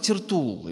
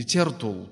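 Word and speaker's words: Тертулл: Кто такой Тертул или Тертул? По-разному ставят ударение на этого человека.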